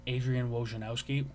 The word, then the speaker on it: Adrian Wojnarowski: Adrian Wojnarowski.